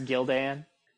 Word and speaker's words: Gildan: I believe it's pronounced Gildan.